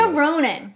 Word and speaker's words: Saoirse Ronan: I want to see Sorcy Ronan, whatever... Saoirse? – A lot of people gave you instructions on how to say this. – Saoirse Ronan.